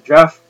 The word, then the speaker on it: Geoff: G-E-O-F-F. – I thought you just pronounced that Jeff. – I thought that was spelled J-E-F-F. – I've seen Jeff and Jeffrey spelled with a G-E-O-F.